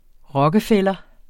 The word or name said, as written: rockefeller